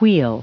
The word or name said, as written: weal